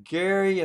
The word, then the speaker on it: Gerry: They quit calling him Gerry and started pronouncing it Jerry, and the word gerrymander was added to the English language.